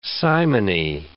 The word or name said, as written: simony